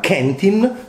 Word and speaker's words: Quentin: un altro Quentin che Luca Guadagnino chiama Kentin, perché è la pronuncia corretta.